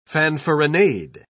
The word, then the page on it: fanfaronade: British English